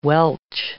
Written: welch

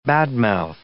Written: bad-mouth

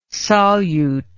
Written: salyut

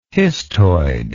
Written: histoid

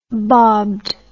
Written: bobbed

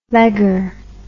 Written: legger

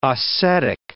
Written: Ossetic

